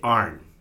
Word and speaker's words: on: Now, I do know, and I've embraced it, I do have from Nebraska, we don't have an accent, but somehow, how do you say the word O-N? – On. – Well, I say arn. – Oh, that's weird. You add an R. – Arn. I add an R. – A-R-N? – Arn.